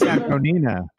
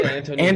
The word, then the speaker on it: Antonina: What I think it's Antonina. – Yeah, Antonina. – Antonina.